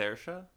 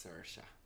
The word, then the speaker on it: Saoirse: And but then, is it Saoirse? – No, it's Saoirse. – Oh, I've been saying it wrong.